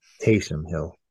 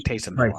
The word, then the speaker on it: Taysom: But good to see Tayshaun Hill getting back in on the action. – Taysom Hill. – I knew I was saying that wrong. Taysom Hill.